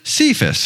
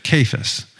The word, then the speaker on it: Cephas: And I have to confess that until this week, I have always pronounced it Cephas, because that's what it looks like, right? C-E, Cephas. But it turns out that we're supposed to pronounce it Cephas.